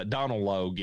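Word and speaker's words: Donal Logue: Donald Logue, yeah. That's how you say his name.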